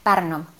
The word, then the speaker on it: Pärnu: Und wenn ich dann in Tartu wirklich die ganze Historie und Kunstkultur eingesaugt habe, dann fahre ich weiter nach Pärnu. Ist das richtig ausgesprochen? – Ja, Pärnu. Pärnu ist ein alter, schöner Badeort, wo auch viel, besonders in den Sommermonaten, stattfindet.